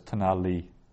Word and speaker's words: Tanali: So you'd pronounce that as Tanali. Just run it off. Well, once your Hebrew gets good enough to tackle it all as one word, you just run the whole thing on Tanali, accent on the li.